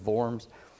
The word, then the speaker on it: Worms: We say Worms, but in German it's Worms.